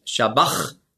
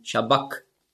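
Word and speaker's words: sabachthani: Also nicht Shabach, sondern Shabak.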